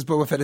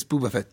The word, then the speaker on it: Boba Fett: Vincent you know, he mispronounces Boba Fett as Booba Fett.